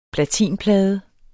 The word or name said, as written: platinplade